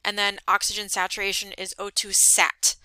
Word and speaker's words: O2 sat: Um, I hear a lot of students and, and even other fellow nurses say stat. Um, so you want to make sure that you're saying it correctly. So it's O2 sat saturation. We're